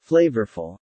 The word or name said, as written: flavorful